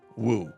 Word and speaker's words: Wu: And then his last name is just W U woo.